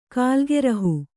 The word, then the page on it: ಕೇಲಿಗೃಹ: kēli gřha